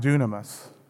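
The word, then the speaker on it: dunamis (δύναμις): Now, the word power in the original Greek is pronounced dunamis, and it's our foundation for the word dynamite.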